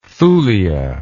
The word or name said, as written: thulia